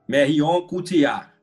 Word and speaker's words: Marion Cotillard: Marion Cotillard.